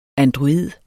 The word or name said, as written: android